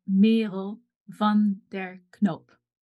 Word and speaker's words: Merel van der Knoop: So the way that you'd pronounce it in Dutch is Merel van der Knoop.